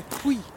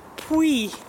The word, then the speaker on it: Puy: Pie lentils. How you say it? P-U-Y. – What do you call them, pie? – I panicked there. Pie lentils? I'm never sure. – Pui. – Pui.